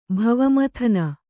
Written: ಭವಮಥನ

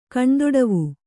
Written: ಕಣ್ದೊಡವು

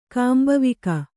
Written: ಕಾಂಬವಿಕ

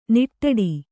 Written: ನಿಟ್ಟಡಿ

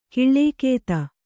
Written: ಕೂಳ್ಗುದಿ

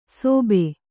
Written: ಸೋಬೆ

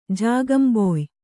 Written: ಜಾಗಂಬೊಯ್

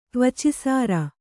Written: ತ್ವಚಿಸಾರ

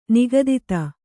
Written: ನಿಗದಿತ

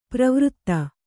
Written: ಪ್ರವೃತ್ತ

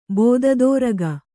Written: ಬೋದದೋರಗ

